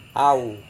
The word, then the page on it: au: Serewen